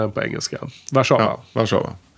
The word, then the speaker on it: Warszawa: Warszawa mm. uttalas det. – Ja. Inte engelska utan, nej, nej.